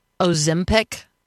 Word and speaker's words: Ozempic: So when we come back from a very brief break, can we talk about Ozempic? Am I pronouncing it right? – Absolutely. – You got it.